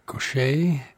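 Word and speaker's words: Gauthier: And Mercy Now by Mary Gaucher. It's not spelled that way, but that's how it's pronounced.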